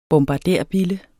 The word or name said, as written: bombarderbille